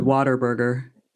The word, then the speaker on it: Whataburger: We pronounce it Whataburger. Wow. Like water, like Whataburger. Water. But it's what a burger, you know, but we say Whataburger. So if you're local, you would say Whataburger.